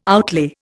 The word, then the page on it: outlay: Indian